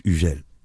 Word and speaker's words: Hugel: Now, is it Hugel or Hugel? That's the best way to pronounce it. – It's Hugel. – Hugel. – Hugel. – Hugel. – Am I going to say it right? – You said it like French, like top.